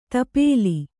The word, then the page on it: ತಿಪ್ಪಲಿ: Kannada